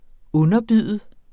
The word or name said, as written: underbyde